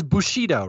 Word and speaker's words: Bushido: Which is Bushido, right? It's pronounced Bushido. I should get coached to answer this, but he says Bushido, which is not correct.